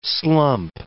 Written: slump

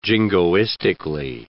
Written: jingoistically

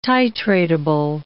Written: titratable

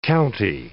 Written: county

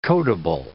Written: codable

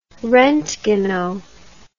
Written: rontgeno

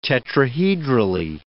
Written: tetrahedrally

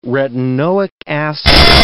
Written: retinoic acid

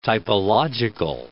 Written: typological

